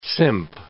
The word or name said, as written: simp